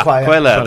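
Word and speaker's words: Cuéllar: Celiar, is it Celiar? Carlos Celiar? I can't say it right. – Oh, Quella. – Quella, there we go. – Celiar. – Celiar. I'm saying it as it's written. – Is it C-U-E? – Yeah.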